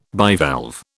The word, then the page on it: bivalve: British English